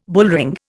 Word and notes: bullring: Indian

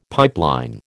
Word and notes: pipeline: American English

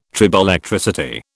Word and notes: triboelectricity: British English

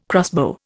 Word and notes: crossbow: Indian

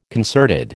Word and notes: concerted: American English

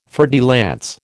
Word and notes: fer de lance: American English

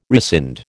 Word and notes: rescind: American English

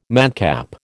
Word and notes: madcap: American English

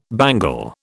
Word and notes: bangle: British English

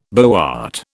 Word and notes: boart: British English

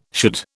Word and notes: should: British English

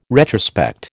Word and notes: retrospect: American English

